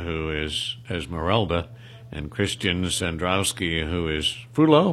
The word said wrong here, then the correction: Frollo: am I pronouncing that correctly? – Frollo.